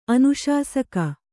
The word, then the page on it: ಅನುಶಾಸಕಿ: Kannada; anuśasaki